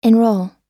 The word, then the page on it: enrol: American English